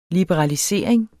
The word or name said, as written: liberalisering